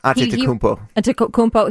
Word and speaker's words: Antetokounmpo: I was like, no, he's got, I don't even know how to say it, Giannis Antetokounmpo. – You got it. Antetokounmpo.